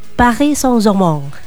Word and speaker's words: Paris Saint-Germain: Bacanya jangan PSG-PSG lagi, ngomongnya Parle français ya, jadi Paris Saint-Germain.